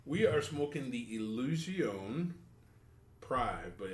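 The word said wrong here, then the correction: Privé: No, it's Prive. Okay. Do you see that little accent on the E? Uh-huh. Above the E, that's why you know it's Prive.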